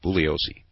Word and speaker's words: Bugliosi: Ooh, I've got to learn that. Bugliosi. The G is silent.